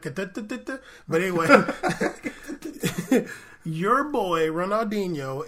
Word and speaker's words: Querétaro: He did make us learn how to pronounce Querétaro, though. – That's true. – That word, we see it in front like, damn, that's a lot going on there. Querétaro, okay, got a QE and an R and a T.